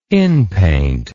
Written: inpaint

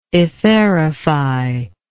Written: etherify

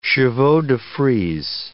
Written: chevaux-de-frise

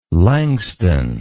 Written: langston

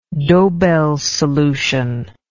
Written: dobell's solution

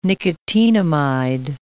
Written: nicotinamide

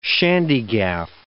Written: shandygaff

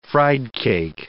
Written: friedcake

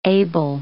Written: able

